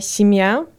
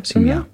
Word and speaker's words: сім'я: Це моя сім'я albo це моя родина. – Сім'я. – Dokładnie. – Piszemy tak, jak ja wymawiam teraz? Simia po prostu? – Simia.